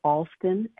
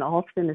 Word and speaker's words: Alston: They can also go to my Instagram account, Karen Mills Alston, and Alston is spelled A-L-S-T-O-N.